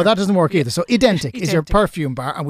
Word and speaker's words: Ydentik: It's called Ydentik. – Ydentik.